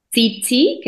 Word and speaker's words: Tzitzi: Este, mi, mi Instagram es Tzitzi, que se escribe T-Z-I-T-Z-I, punto B de, bueno, M.